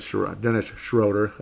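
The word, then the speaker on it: Schröder: Dennis Schroeder. It's every other pronunciation of that. It always looks like Schroeder, but every pronunciation is Schrader, except this guy.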